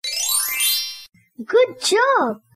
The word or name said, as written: Good job